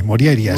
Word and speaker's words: Morjaria: Bueno, la J normalmente se pronuncia como I, Morjaria.